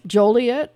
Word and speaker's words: Joliet: Joliet? – Yes. Yes. Joliet is correct.